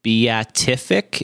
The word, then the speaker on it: beatific: So I know you have a great breakdown of the beatific.